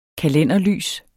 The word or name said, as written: kalenderlys